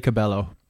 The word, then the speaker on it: Cabello: Is it Camila Cabello? Is it neither of those? – We say Cabello.